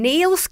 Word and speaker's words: Nhill: Neil is, it's in the Wimmera region and it's on the Western Highway. It's between Adelaide and Melbourne. Now the name Neil, which is spelled N-H-I-L-L, it's indigenous.